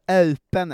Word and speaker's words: Eupen: öpen är det det ska uttalas. Eh, som i, ett sånt rör man eh, vänder upp och ner.